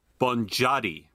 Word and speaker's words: Boniadi: I looked it up. It's Boniadi. – Okay. – Nazanin is correct, but Boniadi. I was like, okay, Boniadi. Fine.